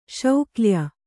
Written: ಶೌಕ್ಲ್ಯ

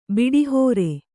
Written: ಬಿಡಿಹೋರೆ

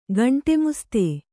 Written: ಗಂಟೆಮುಸ್ತೆ

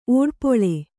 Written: ಓಡ್ಪೊಳೆ

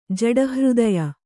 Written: ಜಡಹೃದಯ

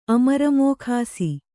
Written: ಅಮರಮೋಖಾಸಿ

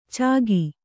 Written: ಛಗ

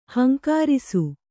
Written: ಹೂಂಕರಿಸು

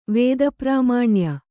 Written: ವೇದಪ್ರಾಮಾಣ್ಯ

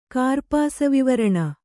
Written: ಕಾರ್ಪಾಸವಿವರಣ

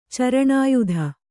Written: ಚರಣಾಯುಧ

